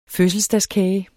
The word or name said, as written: fødselsdagskage